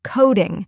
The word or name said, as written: coding